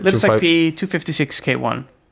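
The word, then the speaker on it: libsecp256k1: Okay, so libsec 256, am I saying that right? – libsecp 256k1.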